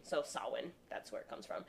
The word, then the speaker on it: Samhain: So the holiday roots stem from Samhain.